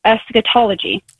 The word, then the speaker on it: eschatology: If I'm pronouncing it right, it's like eschatology.